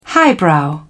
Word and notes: highbrow: British English

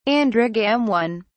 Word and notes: androgamone: British English

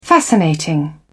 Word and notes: fascinating: British English

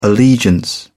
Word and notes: allegiance: British English

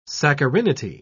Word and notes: saccharinity: British English

